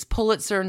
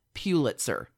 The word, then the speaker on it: Pulitzer: Palm Beach businesswoman, heroine, bravado goddess, Lilly Pulitzer. – Huh. – And her divorce from her first husband, Peter Pulitzer. I'm going to go ahead and emphasize Pulitzer. It's Pulitzer, not Pulitzer.